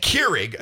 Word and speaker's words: Keurig: Keurig.